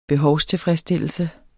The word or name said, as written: behovstilfredsstillelse